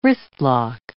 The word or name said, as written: wristlock